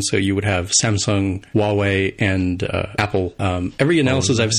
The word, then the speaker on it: Huawei: is it Highway? I don't know how to say the name of the company, the Android developer. They apparently are Huawei.